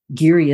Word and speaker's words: Giere: listening, Giere is spelled G-I-E-R-E.